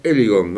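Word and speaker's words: Heligon: Y estamos en comunicación con la profesora Gabriela Eligón. No sé si pronuncio mal. Buenas tardes, ¿cómo le va, profesora? – Hola, buenas tardes. No, está muy bien pronunciado.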